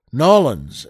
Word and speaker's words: New Orleans: She plays Dennis Quaid's mother on this, in this family of Cajuns, well, down in the Big Easy, New Orleans, or Nolens, as they more likely pronounce it down there.